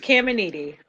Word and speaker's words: Caminiti: It's Caminiti.